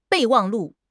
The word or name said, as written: 备忘录